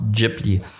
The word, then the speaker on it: Ghibli: Und im Japanischen wird das G allerdings als J ausgesprochen, also stimmhaft Ghibli.